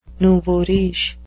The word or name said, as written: nouveau riche